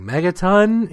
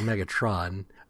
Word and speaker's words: Megatron: There's a guy saying, Megaton? He's like, Megatron.